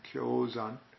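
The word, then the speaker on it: Gyōzan: This is a Japanese way of pronouncing his name, Gyozan.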